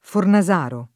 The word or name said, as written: Fornasaro